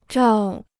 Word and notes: 照: zhào

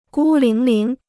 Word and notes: 孤零零: gū líng líng

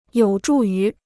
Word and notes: 有助于: yǒu zhù yú

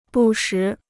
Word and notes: 不时: bù shí

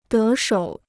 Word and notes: 得手: dé shǒu

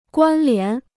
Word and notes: 关联: guān lián

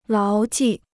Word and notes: 牢记: láo jì

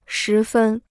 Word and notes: 十分: shí fēn

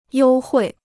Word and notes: 优惠: yōu huì

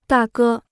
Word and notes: 大哥: dà gē

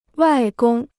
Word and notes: 外公: wài gōng

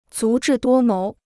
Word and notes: 足智多谋: zú zhì duō móu

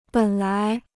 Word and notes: 本来: běn lái

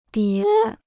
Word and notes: 叠: dié

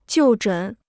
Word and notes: 就诊: jiù zhěn

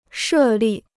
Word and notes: 设立: shè lì

